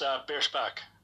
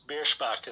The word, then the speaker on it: Bierschbach: It's Bierschbach. – Say that one more time. – It's Bierschbach is how Bierschbach.